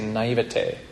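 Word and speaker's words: naïveté: How do you say it? Naivete. Yeah, there you go.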